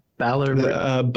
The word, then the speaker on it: Balearic: How do you say it again? – Balearic. – Balearic. Okay, good. So the influences,